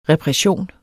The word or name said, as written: repression